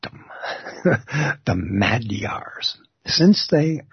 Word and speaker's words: Magyar: Now, you'll see the word Magyar, and it's spelled M-A-G-Y-A-R. And some people will automatically say Magyars, like there's a hard G in it. But it's a soft G, and it comes out sounding like a D.